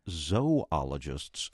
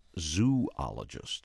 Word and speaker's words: zoologists: And a pronunciation tip from listener Buddy Mays, who liked our story about shark researchers, but notes those who study animal life are zoologists, not zoologists.